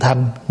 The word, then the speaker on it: thinh: Chữ thinh nó có thể đọc là chữ thanh, nguyện thử chung thanh siêu pháp giới.